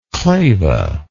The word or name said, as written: claval